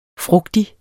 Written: frugtig

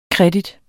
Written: credit

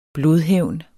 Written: blodhævn

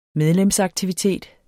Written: medlemsaktivitet

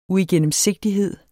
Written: uigennemsigtighed

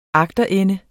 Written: agterende

